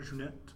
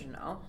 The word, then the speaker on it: Jeunet: But, yeah, Amelie, directed by, I already probably butchered his name, but it was directed by Jean-Pierre Jeunet. – It's probably pronounced Jeunet.